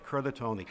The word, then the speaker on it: Curtatone: And I don't know, Curtatone is did I pronounce that properly? – I believe so. – Okay. No, I think it's actually Curtatone. – Okay, Curtatone.